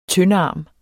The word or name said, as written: tyndarm